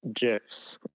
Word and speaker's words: GIF: Dude, GIFs. I'm telling you. – Isn't it? Dude, it's GIFs. I'm a GIF maxi. Hard G.